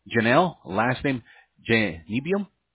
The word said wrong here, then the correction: Bynum: That's Janelle Bynum is how you pronounce her name.